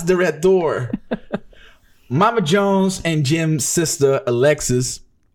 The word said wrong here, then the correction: Alexius: It's not Alexis. It's Alexius.